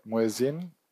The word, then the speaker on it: muezzin: You know, singing or liturgy or, you know, this ritual, you know, I wake up in the morning listening to a, how do you pronounce it, muezzin? Soft, muezzin, soft pronunciation, muezzin.